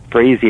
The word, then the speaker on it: Frazee: And again, you just have to put my name, James Frazee, and it will come up. And the way I tell my students to remember, Frazee, it rhymes with lazy and crazy.